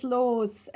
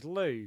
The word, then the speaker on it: Loos: It's her last name. It's Laws. L-O-O-S. – Oh, Laws. So it's pronounced Laws in German. – Laws. Oh, so it's Laws, Laws. Oh, see, we'd pronounce that as Lou, because it'd be a silent S. – I know, yeah, but it's not.